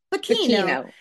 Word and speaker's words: Bucchino: How do you say his Bucchino. – Last name? – Bucchino. – Yeah.